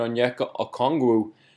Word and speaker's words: Onyeka Okongwu: The Hawks, against all odds, kept the number 6 pick, kept the number 50 pick, and selected Onyeka Okongwu. I need to know what you think here. First reactions. – First reactions is congratulations. You got the pronunciation correct.